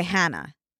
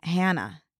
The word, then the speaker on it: Hannah: I used to have like a really, really, like noticeable way of saying my A's, like from Philly. Like my middle name is Hannah, right? – Hannah. – How would you say that? – Hannah. – So I would always say Hannah. Like, I'd be Hannah. Like, Brooke Hannah. And now I've noticed myself saying Hannah. Is that how you say it? – I can't tell now.